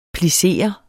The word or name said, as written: plissere